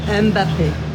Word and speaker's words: Mbappé: Mbappé. – Mbappé.